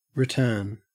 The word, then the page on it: return: Australian English